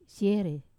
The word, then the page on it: se: Wamesa